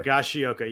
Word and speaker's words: Higashioka: Higashioka, I always added Higa- a syllable there. Higashioka, yes.